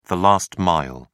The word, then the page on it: last mile: British English